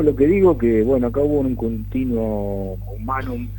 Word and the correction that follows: continuum humanum: sería un continuum humanum, se dice así en latín, sí.